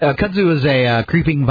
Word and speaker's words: kudzu: Bigger than ever, and growing like kudzu, thanks to the free spending... I hope I pronounced that right. – It's kudzu.